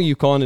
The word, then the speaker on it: Yukon: It's called The Wild Yukon. Am I am I saying that right, Greg? Yep. So it's The Wild Yukon.